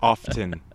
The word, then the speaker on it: often: Yeah, yeah, often. Which I actually read the, I looked up the history of often, and actually that, historically that T wasn't pronounced either.